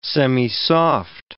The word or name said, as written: semisoft